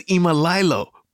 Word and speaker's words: Imlaylow: Okay, so that was Imlaylow, but it's spelled Imlaylow, like I'm gonna lay low. That's very cool. I love that play on words.